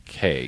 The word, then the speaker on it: Cay: Do they say Cay or Key? – Cay. – Because when you go to Florida, and they always say Key, even though they spell it C-A-Y.